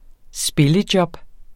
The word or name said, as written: spillejob